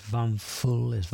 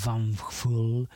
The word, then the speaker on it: Hvalfugl: Se llaman Vakful, eh, a ver si lo he dicho bien, es Vamful, así es como se llaman, H-V-A-L-F-U-G-L, Vamful, y son de Arus, de Dinamarca.